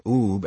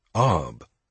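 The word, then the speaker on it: Ubbe: In accidental reciprocity, Walt kept mispronouncing Oob as Ub.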